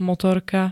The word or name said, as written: motorka